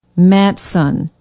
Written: matteson

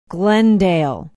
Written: glendale

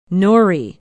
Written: norrie

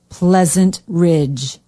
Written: pleasant ridge

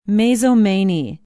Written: mazomanie

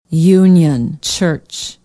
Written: union church

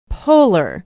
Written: polar

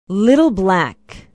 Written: little black

